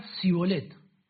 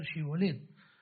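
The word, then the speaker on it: Shibolet (שיבולת): Si él respondía no, entonces le decían, ahora pues, di Shibolet. Y él decía Sibolet, porque no podían pronunciarlo correctamente.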